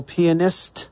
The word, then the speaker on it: pianist: I'm your host, and we're here talking to super rad dude, shredding classical pianist. Got to make sure you say that right. – Pianist. – Get the T out of it.